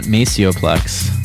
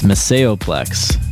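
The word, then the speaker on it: Maceo Plex: And I guess I was saying his name wrong. It's not Maceoplex, it's Maceoplex.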